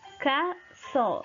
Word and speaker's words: castle: Silent T, la T silenciosa. Butcher. En vez de pronunciar la T, vamos a hacer como que una pausa. Butcher, butcher, carnicero. Castle, castle, castillo. Y Christmas, Christmas, Navidad.